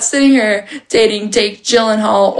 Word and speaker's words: Gyllenhaal: I now learned how to pronounce his last name, Gyllenhaal.